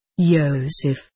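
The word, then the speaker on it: Yosef (יוסף): Though Moses was of noble descent, 'tis said that he was of the house of Joseph, pronounced Yosef.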